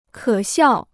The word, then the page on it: 可笑: kě xiào